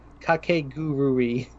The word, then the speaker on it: Kakegurui: K-A-K-E-G-U-R-U-E. I can't even spell it. Yeah, someone in chat said it. Kakegurui. – There it is. – There you go. You nailed it.